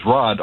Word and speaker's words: Rodd: I'm calling to say my name on my birth certificate is Rod, R-O-D-D, and I always tell people when they ask me if that's short for something else, I say no.